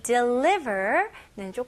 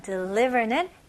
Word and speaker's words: deliver: 그래서 여기서 스펠링이 E, 첫 번째 모음은 E, 두 번째 모음은 I잖아요. 근데 발음이 비슷해요. deliver. deliver. 오케이? deliver라고 발음하는 경우가 거의 없습니다. 오케이? 다시 해볼게요. deliver. deliver. 오케이. 강세는 li에 해주시면 좋고요.